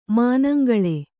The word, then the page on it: ಮನಂಗಲ್: manangal